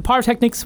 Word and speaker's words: pyrotechnics: But the pyrotechnics, what's that again? – And that's not a silent P like pterodactyl.